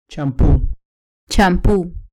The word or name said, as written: champu